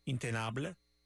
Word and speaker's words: Intenable: I'm sure I'm saying that wrong. They're French, so it's Intenable.